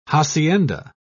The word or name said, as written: hacienda